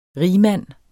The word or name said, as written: RIGMANDENS